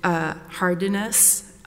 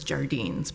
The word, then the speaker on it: Jardines: In Hardiness, and I'm going to just say that I believe that's the right pronunciation, although I think the Chief Justice says Jardines, but that I believe it is Hardiness.